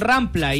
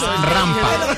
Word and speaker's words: rampa: No, es que sí, pero dijeron rampla y no se dice rampla. Y no rampa, se dijo rampla y es rampa.